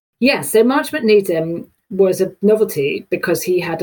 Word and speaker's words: Nedham: Marchamont Nedham? – Yes, or Needham.